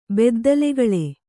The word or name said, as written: ಬೆದ್ದಲುಗಳೆ